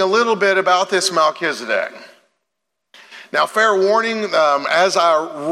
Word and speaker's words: Melchizedek: We've got Genesis chapter 14, where Melchizedek is mentioned in his time with Abraham and what went on there.